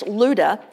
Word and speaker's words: Lydda: When these disciples heard that Peter was in, it looks like Lydda, but it's actually pronounced Luda, they sent two men to him and urged him, "Please come at once."